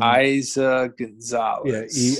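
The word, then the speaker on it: Eiza González: Aiza Gonzalez. She was in. – Aiza Gonzalez. – Yeah, E-I-Z-A.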